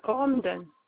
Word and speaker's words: Ramdane: Romden. – Ça s'écrit? – O-U Oui. – R-A-M-D-A-N-E.